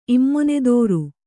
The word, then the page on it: ಇಮ್ಮೊನೆದೋರು: Kannada